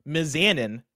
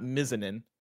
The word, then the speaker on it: Mizanin: The Miz's last name is pronounced Mizanin, not Mizanin.